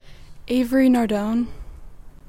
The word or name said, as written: Avery Nardone